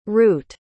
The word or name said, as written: reut